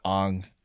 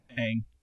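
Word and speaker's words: Aang: His name is not Ong. – Ang.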